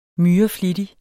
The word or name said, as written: MYREFLITTIGE